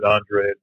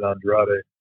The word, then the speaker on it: Andrade: No, I was just saying, I think he pronounces it Andre, but some fighters that were from Brazil in the past have pronounced it Andrade.